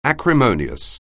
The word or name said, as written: acrimonious